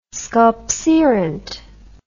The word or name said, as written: sculpserunt